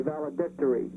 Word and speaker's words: valedictory: I think the accented syllable is D-I-C, valedictory.